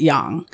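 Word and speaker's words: yang (阳): But originally Chinese people would pronounce it yang, and the way that it gets translated into English is called pinyin, because obviously Chinese has different like characters.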